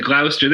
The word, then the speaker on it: Gloucester: You said Gloucester. That's how they pronounce it up there.